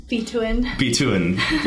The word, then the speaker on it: Bituin: Bituin.